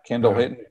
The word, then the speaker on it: Kendall Hinton: And it was, and you had Kendall Hinton being your, is that Kendall Hinton? I get his name right. – Yeah, Kendall Hinton, practice squad receiver.